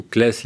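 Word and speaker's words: Claeys: Donc troisième point aussi fondamental de la loi Leonetti, alors qu'on appelle maintenant la loi Clé Leonetti ou Clès Leonetti, oui, selon qu'on le prononce, Alain Clé et Jean Leonetti, deux hommes politiques.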